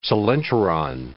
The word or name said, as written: coelenteron